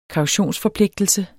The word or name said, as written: kautionsforpligtelse